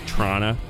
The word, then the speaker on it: Toronto: The correct way to spell, or to spell, the way to spell the correct pronunciation of Trana is C-H-R-A-N-A. – Yeah. – Maybe a double N at the end, like Trana.